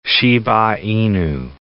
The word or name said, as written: Shiba Inu